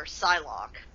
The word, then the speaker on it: Psylocke: I always call her Psylocke, but I'm not sure if that's how you actually...